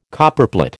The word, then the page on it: Copperplate: American English